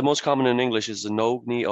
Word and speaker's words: Nogne: How should I pronounce the name of the brewery? – Traveling around the world, I've heard it pronounced so many different ways, but in Norwegian we say Nogne.